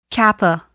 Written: kappa

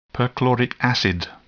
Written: perchloric acid

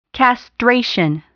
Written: castration

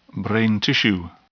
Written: brain tissue

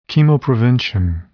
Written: chemoprevention

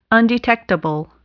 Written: undetectable

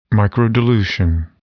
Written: microdilution

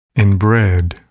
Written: inbred

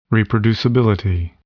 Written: reproducibility

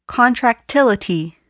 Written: contractility

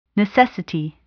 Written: necessity

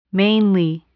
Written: mainly

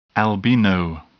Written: albino

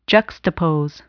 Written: juxtapose

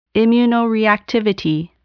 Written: immunoreactivity

